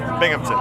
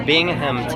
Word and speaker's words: Binghamton: Is that right? – Binghamton. – How do you say it? – Binghamton. Yeah. – Oh, I spelled it wrong. How do you spell it? – B-I-N-G. – Oh. – H-A-M. – Oh. Why didn't you tell me? – Sorry, man. – Binghamton. – Yeah, Binghamton. Okay.